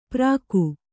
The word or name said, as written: ಪ್ರಾಕು